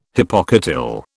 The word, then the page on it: hypocotyl: British English